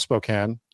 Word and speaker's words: Spokane: And yeah, it's pronounced Spokane, you idiot, Jesse.